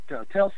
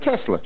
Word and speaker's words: Tesla: I mean, pronouncing his name right, Tulsa? – Mm-hmm. Tesla.